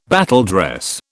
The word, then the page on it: battledress: British English